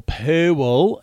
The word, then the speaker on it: pool: Or Purwell, as it's pronounced in this country. Yes, there are two syllables, depending on your generation of Australian.